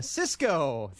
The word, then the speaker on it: Sisqo: Cisco, the thong song.